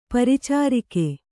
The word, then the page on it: ಪರಿಚಾರಕಿ: paricāraki; kannada